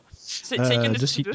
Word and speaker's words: Seiken Densetsu: Seiken Densetsu 2. Je ne sais pas si je le dis bien. – Seiken Densetsu 2, c'est ça.